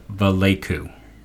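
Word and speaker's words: Valaika: Chris Vallecu. I believe I said it right. I went and I listened to a pronunciation guide just to have a better chance of getting this correct.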